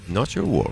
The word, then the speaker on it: Not Your War: Not Your War. – Not Your War. – Ho detto giusto? – Giustissimo. Detto giusto.